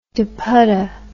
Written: dupatta

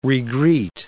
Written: regreet

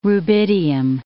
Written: rubidium

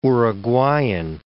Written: Uruguayan

